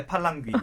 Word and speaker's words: Palanggui: Yeah, Palanggui. Okay. It's like the ear is like palang-palang.